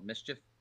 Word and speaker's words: MSCHF: Yeah, no, it was made by a New York art collective group called Mischief. M-S-C-H-F is how they spell it. Mischief.